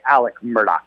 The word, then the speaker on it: Alec Murdaugh: Okay, it's Murdaugh. – With a CK sound. – Hello, country. Alec is what most are saying, although if you want to be real technical in the Low Country, they say Alec Murdaugh. But most go by Alec Murdaugh.